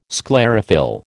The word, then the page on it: sclerophyll: American English